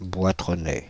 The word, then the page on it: boitronnais: /bwa.tʁɔ.nɛ/; France (Île-de-France)